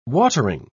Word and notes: watering: British English

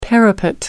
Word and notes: parapet: American English

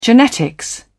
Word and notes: genetics: British English

